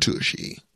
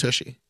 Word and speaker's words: Tucci: It's pronounced Tucci.